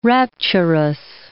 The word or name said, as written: rapturous